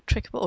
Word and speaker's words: Tricable: It was the name of it comes from try and cable, but I pronounce it trickable because I think it sounds nicer.